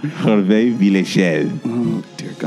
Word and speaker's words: Hervé Villechaize: Hervé Villechaize.